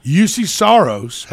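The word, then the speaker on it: Juuse Saros: The Predators won 2-1 over the LA Kings last night in Los Angeles, as Yusei Saros, is that the way he pronounces? – Yusei Saros had 33 saves for a .971 save percentage, which is pretty good.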